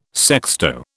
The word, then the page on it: sexto: British English